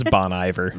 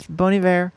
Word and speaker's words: Bon Iver: I didn't know that that was how you pronounce his name for a very long time. I thought it was Bon Iver. – Bon Iver. No, yeah. It's Bon Iver because it's supposed to be Good Winter in French.